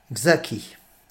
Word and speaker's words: Czacki: Il va parler de l'action du nonce de Léon XIII en France, Mgr Zacki. Ça s'écrit C-Z-A-C-K-I.